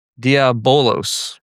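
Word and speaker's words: diabolos (διάβολος): The Greek for the devil in this is diabolos. I said it right this time.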